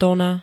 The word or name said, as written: tona